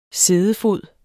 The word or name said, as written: siddefod